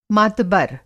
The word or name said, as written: मातबर